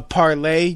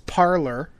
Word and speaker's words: Parler: That's P-A-R-L-E-R. That's P-A-R-L. I mean P-A-R-L-E-R. So it's parlor, but it's pronounced parlay, as in like a free speech. Haha, free speech in French.